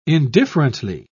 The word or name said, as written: indifferently